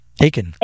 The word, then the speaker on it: Achan: Is it Achan? – Yeah, that's right. Achan. Like belly Achan.